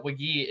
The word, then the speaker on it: Wague: We found out Wague is the correct pronunciation of his last name.